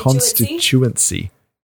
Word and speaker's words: constituency: over a wider constituency. – constituency. Constituency. Thank you. You're constituency. Putting the emphasis on the wrong syllable.